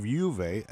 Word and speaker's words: Juve: That is Juve. – That's Juve. You said it right.